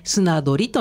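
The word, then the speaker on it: 漁り: はい、はい、漢字で書くと、山水に魚と書きます、はい、で送り仮名の利を書きまして、うん、砂取りと読みます、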